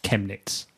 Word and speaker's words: Chemnitz: AdminJS in IRC, who's listening live, has just told me it's pronounced Chemnitz.